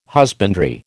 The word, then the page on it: husbandry: American English